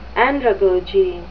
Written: andragogy

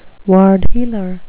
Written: ward heeler

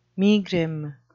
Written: megrim

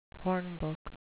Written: hornbook

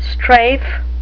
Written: strafe

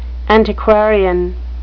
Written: antiquarian

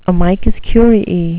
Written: amicus curiae

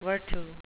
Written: virtu

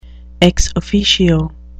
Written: ex officio

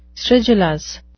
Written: stridulous

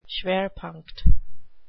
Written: schwerpunkt

